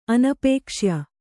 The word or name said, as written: ಅನಪೇಕ್ಷ್ಯ